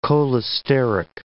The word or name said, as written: cholesteric